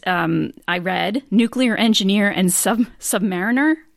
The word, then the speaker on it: submariner: What's funny is there's actually, there's an argument between submariners about whether you say submariner or submariner. I like submariner because it sounds better. I've been told by more submariners that it's actually submariner, but I'm like, whatever, potato, potato.